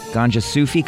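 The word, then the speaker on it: Gonjasufi: Ganja Sufi cover? – Mm-hmm. – Is that right? – Yes. – How I say that? – That's how you say it.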